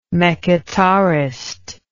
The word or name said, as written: mekhitarist